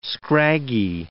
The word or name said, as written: scraggy